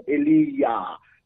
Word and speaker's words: Elijah: Because remember, there are those ones who pronounce the name of the prophet Elijah or Eliyah.